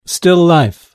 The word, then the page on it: still life: British English